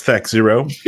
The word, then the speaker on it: THAC0: Or First, it's Thack Zero. pronounce it right.